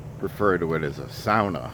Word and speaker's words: sauna: Well, apparently, it's a Finnish word. And Americans kind of just got lazy with it and just called it a sauna. But in its true Finnish form, it is pronounced sauna.